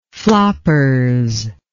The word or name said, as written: floppers